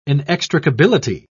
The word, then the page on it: inextricability: British English